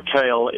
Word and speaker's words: kill: the literal phonetic translation for a kill, kale, is a creek, a small creek, and the Dutch also don't spell fish F-I-S-H, they spell it V-I-S, so it was a fish kale, to use the Dutch.